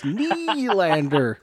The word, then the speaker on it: Nylander: But Ashton, do you realize here we're talking to the very pro, the very guy that I think every time I say William Nylander, I have to say it like Rossi does, Nylander. And I can't say it like you, but Ashton, you know what I'm saying? – Yeah.